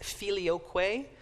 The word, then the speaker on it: filioque: And so this is a Latin word. It's pronounced filioque, and it means, and the Son.